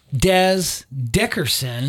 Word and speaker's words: Dez Dickerson: Yeah, and also saw Dez Dickerson. – Mm-hmm. – I think I'm saying that correctly. – Yep.